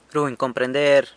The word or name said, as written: runy comprender